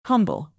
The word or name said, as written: humble